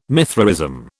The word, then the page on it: Mithraism: British English